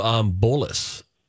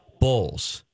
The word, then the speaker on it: Bolles: So the appropriate pronunciation of your last name, B-O-L-L-E-S, is what? – Bowles. – Bowles. Okay. Yes.